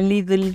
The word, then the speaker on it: Lidl: ¿Cómo suena Lidl? – Lidl Trek, que van a estar estrenando uniforme.